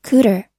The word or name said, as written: cooter